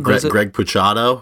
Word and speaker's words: Greg Puciato: Greg Puciato, who was Puciato.